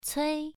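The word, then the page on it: cui: tone 1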